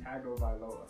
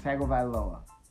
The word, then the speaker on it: Tagovailoa: What is Tua's full name? Pronounce it for me, so I don't. – Tua Tagovailoa. Tagovailoa. – There you go.